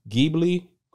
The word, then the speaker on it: Ghibli: It's Studio, I believe it's pronounced Ghibli. Correct me if I'm wrong, y'all.